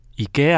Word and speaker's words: IKEA: 哦，oh, 我们在瑞典的话，瑞典文是念作 IKEA 哦。